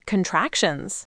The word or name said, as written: contractions